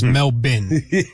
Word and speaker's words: Melbourne: Well, I thought it was Melbourne when we started today, but it's Melbourne, and that is courtesy of Noah Eagle. – Yeah, so Melbourne. The Australian pronunciation, Sal, is Melbourne. – Melbourne.